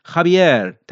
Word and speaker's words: Javier: خابیر تلفظ میشه، حالا اگه من درست خونده باشمش، که توی این دوتا کتاب خانم ملک مرزبان به درستی به همین شکل نوشته شده.